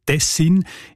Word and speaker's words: Tessin: Auch im Hochdeutschen sieht man Tessin. Die Variante mit der Betonung auf der ersten Silbe und mit kurzem I, also Tessin, ist seltener.